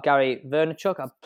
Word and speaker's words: Gary Vaynerchuk: When I first started out, actually, we said about Gary Vaynerchuk. Please tell me if I've said his name right or wrong. – Oh, yeah, better than my name, but go for it.